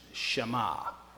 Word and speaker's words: Shema: It's pronounced Shema, S-H-E-M-A is the way it gets translated or transliterated from the old language to ours, Shema.